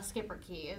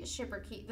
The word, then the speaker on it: Schipperke: Anyway, so as far as our history goes, so the late medieval Belgium was the birthplace of the Schipperke. The Schipperke. I read it wrong. Apparently, it's correctly pronounced the Schipperke.